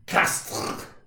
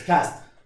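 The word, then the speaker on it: Castres: Castre, is that how you say it in French? – Castre. You hardly touch the RE, man. It's Castre.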